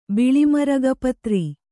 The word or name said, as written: ಬಿಳಿಮರಗಪತ್ರಿ